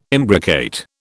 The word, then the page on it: imbricate: British English